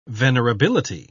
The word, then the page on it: venerability: British English